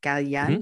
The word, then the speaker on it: Galliano: Galliano.